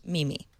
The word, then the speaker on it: meme: I believe it's pronounced Mimi. – Meme. – I have dealt with this controversy many times over the years and have come to the conclusion that both ways are right.